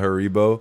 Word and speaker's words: Haribo: Yeah, Haribo.